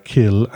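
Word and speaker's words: cill: The Irish word kill, that's C-I-L-L, but it's pronounced kill, and in fact in English you often see K-I-L-L, basically means church.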